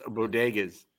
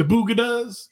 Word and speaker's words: bodegas: She mispronounced bodegas. – The boogadas up in New York.